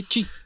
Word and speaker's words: Qi: Bastante bueno, son, son, es de carga inalámbrica con el estándar QI, que se pronuncia? – Sí, chi. chi, ese.